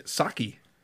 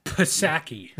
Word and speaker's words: Psaki: Yeah, Saki. – Pisaki. – The P is silent.